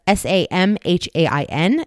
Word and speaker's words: Samhain: So that's S A M H A I N. It is pronounced Samhain. That rhymes with Rose McGowan.